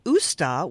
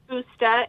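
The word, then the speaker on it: Ásta: So your business name is Forsta, dog training, but you said it's really pronounced Usta, which is Icelandic. Is that correct? – Yes. So it's pronounced Usta, and it means to understand dog.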